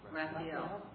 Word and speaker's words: Raphael: Raphael. Am I pronouncing it right? – Raphael.